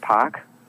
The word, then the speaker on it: Pach: And my name is Rick Pach. And the last name is spelled P-A-C-H.